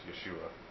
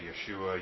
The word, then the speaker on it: Yeshua: it's pronounced Yeshua. You know, it's a bunch of nonsense. That's a big movement right now. There's a bunch of people who's, you have to say Yeshua, you pray in the name of Yeshua, that's the proper way to say it.